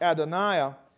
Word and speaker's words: Adonai: But when you see capital L with little O-R-D, that's a word that is pronounced Adonai.